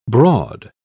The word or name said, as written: broad